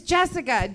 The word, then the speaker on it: Jesika: It's Jessica, J-E-S-I-K-A.